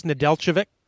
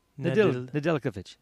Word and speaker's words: Nedeljkovic: the Calder for the league's best rookie, Alex Nedeljkovic. – Yeah. – Did I say that right? – I think you get it different every time, but it's right this time.